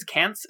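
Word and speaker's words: Caunce: I think it's Kance is how it's pronounced. It's C-A-U-N-C-E.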